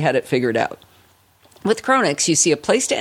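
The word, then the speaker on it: Cronnix: A quick download of Chronix, and we had a tool that was so simple to use, even I could figure it out.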